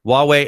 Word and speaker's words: Huawei: Huawei, and then Intel and Niantic.